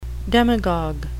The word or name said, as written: demagogue